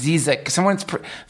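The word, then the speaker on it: Žižek: It's actually pronounced Zizek.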